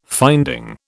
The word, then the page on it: finding: British English